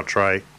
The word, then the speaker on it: Tare: I think it's Tear Duven. – Oh, Trey Devon. – Trey? This is written here as Tear, so. – Right. – I don't know. T-A-R-E. – Yeah, Trey. Yeah, Trey Devon.